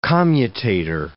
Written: commutator

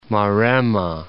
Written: Maremma

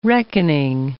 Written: reckoning

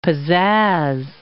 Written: pizazz

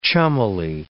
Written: chummily

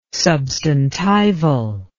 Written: substantival

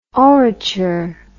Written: orature

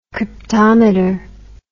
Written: cryptometer

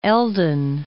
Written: Eldon

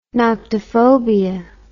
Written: noctiphobia

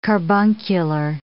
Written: carbuncular

